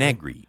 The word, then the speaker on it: Negri: How did you pronounce the person who passed away? – Negri, N-E-G-R-I, Joe Negri.